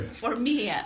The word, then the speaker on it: Formia: Formia.